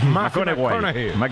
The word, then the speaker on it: McConaughey: Matthew McConaughey. – Sí. – Que hay que ir corrigiendo la pronunciación. – McConaughey.